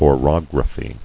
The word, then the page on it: Orography: o·rog·ra·phy